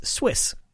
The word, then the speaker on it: SWIS: Lastly, there was actually a whole other telescope on Columbia that I have yet to mention, the Southwest Ultraviolet Imaging System, which goes by an acronym that I'm going to pronounce as SWIS.